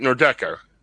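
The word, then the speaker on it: Nordecke: The Nordeca, right? Nordeca. – Yes. Yes.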